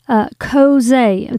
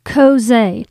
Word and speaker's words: chozeh: And it is kozeh. It's spelled C-H-O-Z-E-H if you want to look that up in your biblical concordance. But it's a Hebrew word that's kozeh.